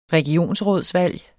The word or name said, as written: regionsrådsvalg